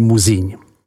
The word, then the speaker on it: Musigny: Prvním jsou právě tyto tři obce a tím druhým je obec v Côte Nuit s názvem Musigny, vyslovuj Muzíň.